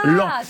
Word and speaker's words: Laon: Ah oui, ça s'écrit Laon, mais ça se prononce Lens.